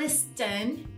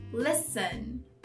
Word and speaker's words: listen: Silent T a gente encontra na palavra escutar, listen. Não é listen, listen, listen.